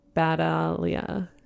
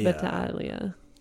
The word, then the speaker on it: Battaglia: It's with a G, but actual in Italian, you don't pronounce the G. Like my dad's good friends growing up were the Battaglias. But if you're really pronouncing it, it's Battaglia.